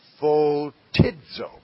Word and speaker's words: photizo: It is this word. P-H-O-T-I-Z-O. And we pronounce that photidzo.